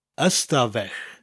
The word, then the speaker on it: stafell: And it's followed by stafell, which means room, spelt S-T-A-F-E-L-L. There's actually a Y in front of the word, ystafell, but it's not usually pronounced in conversation. The Y is dropped and it becomes stafell.